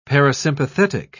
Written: parasympathetic

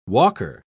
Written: walker

